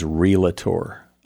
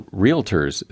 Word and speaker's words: realtor: One of my one of the ones I don't like is relator. There's only a two syllable word, and people often, even realtors, say relator, and I wonder why. – Well, that's a spelling pronunciation. They see there's a sort of rule in English that if the if there are two vowels, usually the first vowel takes precedence, so it's realtor.